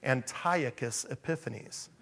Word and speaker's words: Antiochus Epiphanes: Antiochus Epiphanes. Okay? I still think we should call him Antiochus because we have Antioch down the road here. We have, anyway, Antiochus Epiphanes.